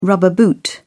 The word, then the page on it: rubber boot: British English